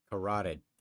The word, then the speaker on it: carotid: Carotid artery, killing him instantly.